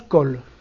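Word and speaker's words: col (כל): Ah, alors ça se prononce col. Col. Qu'est-ce que ça veut dire? – On l'a déjà vu.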